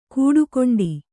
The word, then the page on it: ಕೂಡುಕೊಂಡಿ: kūḍukoṇḍi; kannada